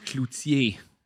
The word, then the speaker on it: Cloutier: Jacques Cloutier.